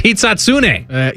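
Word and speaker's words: Hisatsune: Before the 6 o'clock, he walked away from me and he had said the name wrong. And I said, no, it's Hitsatsune.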